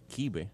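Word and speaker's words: kibbeh: Y el kipe, o el kibe, como se pronuncia en libanés,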